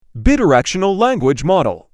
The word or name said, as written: Bidirectional language model